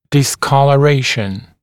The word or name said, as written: discoloration